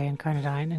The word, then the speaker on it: Incarnadine: Incarnadine? – Well, both pronunciations are correct, but I tend to say Incarnadine.